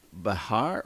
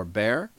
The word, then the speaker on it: Behar: While much of the nation has expressed their concern, some liberal public figures such as The View co-host Joy Behar or Bear, I think it's Bear the way she pronounced it,